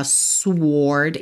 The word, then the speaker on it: sword: Então, perceba que em inglês, o nome do naipe é spades e a palavra espada é sword. Se escreve S-W-O-R-D, mas esse W não é pronunciado, tá? É uma exceção aí. Então, a gente não fala sword e sim sword, ok?